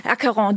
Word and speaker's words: Acheron: Lacheron, ich habe es vorhin falsch gesagt, Lacheron, weil sich so schreibt, aber Acheron bezieht sich auf den griechischen Fluss. – In der Mythologie. – Ja, jawohl, genau. Ja. Acheron, diesmal mit Musik von Johann Michael Nicolai.